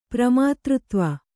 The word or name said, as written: ಪ್ರಮಾತೃತ್ವ